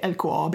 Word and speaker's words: LKAB: So the next step for LKAB, I'm going to say LKAB because that's how you pronounce it in Swedish.